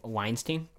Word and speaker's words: Weinstein: Weinstein.